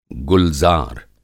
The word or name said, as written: गुलज़ार